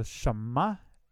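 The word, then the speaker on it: shimá: I think in Navajo, shama is grandma. And you have to say it like that? – Yeah, like the accent like that.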